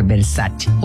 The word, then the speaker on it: Versace: Por ejemplo, Versace, cuando la marca Versace es italiana, entonces tendría que pronunciar Versace.